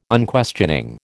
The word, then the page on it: Unquestioning: American English